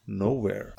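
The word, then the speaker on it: Knowhere: Nowhere. Aber mit K am Anfang. The K is silent.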